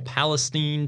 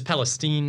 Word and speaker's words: Palestine: And it's Palestine, not Palestine. Get it right. My 903 people out there, let's go. – Well, I mean, Palestine would be how you pronounce it in Spanish because that I becomes an E.